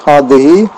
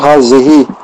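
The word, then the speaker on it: hadehi (هذه): Je répète, hadehi, hada. Ne pas dire hazehi.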